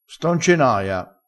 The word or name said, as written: sto